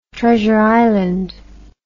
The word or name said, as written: Treasure Island